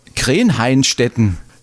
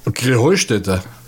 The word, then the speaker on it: Krähenheinstetten: Ja, ihr saget immer Krähenheinstetten. Und dabei heißt es doch Krähenheustätte, oder wie es? – Krähenheustätte. – Ja, genau, und das ist die Betonung auf dem Heu.